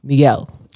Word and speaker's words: Miguel: How do we say your name correctly? – Though? Miguel. – Miguel. – There you go.